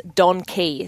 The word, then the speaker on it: Donkee: and she has Don Key. So it's spelt D-O-N-K-E-E, and it's racing tomorrow at Penrith.